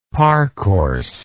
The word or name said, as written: parcourse